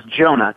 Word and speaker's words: Jonah: My name is Jonah, J-O-N-A-H. I guess that would be my European name. But my Cherokee name was Jonah with a Y, but it's pronounced with a J.